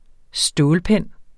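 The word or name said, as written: STÅLPENNE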